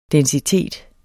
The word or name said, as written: densitet